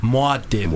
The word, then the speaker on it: Muad'Dib: It's more like Muad'Dib. – Muad'Dib. – Muad'Dib. – Right. Because I'm thinking it's the road, like that's Muad.